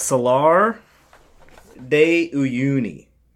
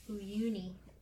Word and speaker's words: Salar de Uyuni: It's Salar de Uyuni. – Uyuni? That sounds... – Uyuni. – Very close to Uwu.